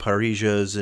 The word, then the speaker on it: parrilla: But you can get those online now if you look up parrillas. And I think there's How do you spell that? – P-A-R-R-I-L-L-A.